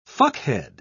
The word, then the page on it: fuckhead: British English